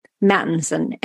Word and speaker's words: Mattinson: It's L-E-A-H-A, like Leah Ha, Mattinson, M-A-T-T-I-N-S-O-N.